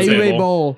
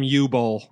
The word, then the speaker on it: Uwe Boll: Yeah, Uwe Boll. – Because for the longest time, I called him U-Boll. Yeah. I wasn't sure how to pronounce U-W-E as a name.